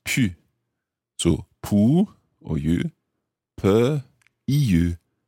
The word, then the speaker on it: pu: The past participle of pouvoir is pu, P-U, pu. Now, notice the pronunciation of that.